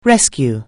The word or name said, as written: rescue